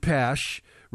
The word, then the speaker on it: Pache: Some pronounce it Pash.